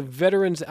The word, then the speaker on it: veteran: and they prefer three syllables and not two. Have you heard that before? – Never heard. That's a first. – Okay. That's a first. So I'm deferring to the Veterans Outreach Center director.